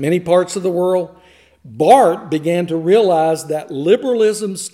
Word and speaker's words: Barth: His name is spelled B-A-R-T-H, but it is pronounced Barth.